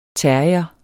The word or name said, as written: terrier